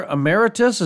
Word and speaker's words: Emeritus: Professor Emeritus, is that how we pronounce it? – That's right.